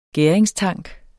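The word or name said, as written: GÆRINGSTANK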